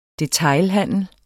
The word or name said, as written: DETAILHANDEL